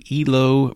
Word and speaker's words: Hilo: Hilo, even though it's spelled like Hilo, Hawaii, actually, as far as we can tell, refers to Ilo, Peru.